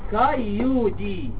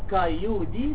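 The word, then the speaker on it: cailliaudi: هنا اسمه ليمنيا ها كايودي ال ال ال سايلنت ما تنطقهمش، ليمنيا كايودي اثنين، انما هنا اسمه ليمنيا برضو تران كاتيولا اثنين.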